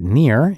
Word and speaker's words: Nir: And please do note that Nir is spelled like his name, N-I-R.